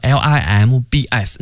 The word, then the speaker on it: limbs: 对，那大家一定注意哈，四肢那个词呢，limbs，那个 b 不发音，就是 limb。